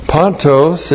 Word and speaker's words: pantos: P-A-N-T, long O-S. Pontos, it means no, not at all.